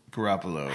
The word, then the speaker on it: Garoppolo: And I can't ever say his last name. Jimmy Garoppolo? – Garoppolo, I said it right.